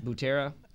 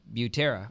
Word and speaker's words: Butera: And How you say that last name again? Butera. How do you know you are saying that right? – because they said it the other day on TV. – Okay, Brutera. – Yeah, Drew Butera.